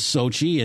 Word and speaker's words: Sochi: I keep on mispronouncing it when I talk to friends and relatives, but it is Sochi, and you know, all I can say is that the games have been interesting, to say the least.